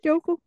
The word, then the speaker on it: Youku: Youku, ajá.